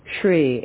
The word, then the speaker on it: Hri: In the center of the moon is the syllable Shri, H-R-I, his seed syllable, his object of concentration.